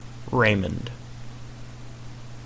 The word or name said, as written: Raymond